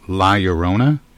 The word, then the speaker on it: La Llorona: La Llorona. Am I saying that right? – Yeah.